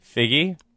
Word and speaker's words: Feige: He basically, I believe it's pronounced Figgy. – Figgy? – Figgy.